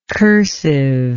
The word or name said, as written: cursiveness